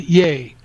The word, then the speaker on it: Ye: I want to jump in real quick, and because I realized listening to Kanye that he said he pronounces it "yay," and I want to ride the man.